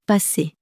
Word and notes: passer: french

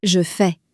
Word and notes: je fais: French

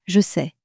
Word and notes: je sais: French

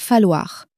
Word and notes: falloir: French

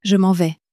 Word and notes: je m'en vais: French

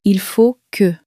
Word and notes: il faut que: French

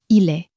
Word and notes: il est: French

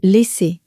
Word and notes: laisser: french

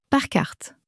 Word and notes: par carte: french

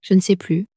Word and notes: je ne sais plus: French

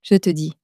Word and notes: je te dis: french

